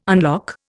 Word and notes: unlock: Indian

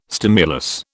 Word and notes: stimulus: British English

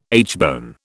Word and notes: aitchbone: British English

